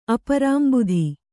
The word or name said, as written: ಅಪರಾಂಬುಧಿ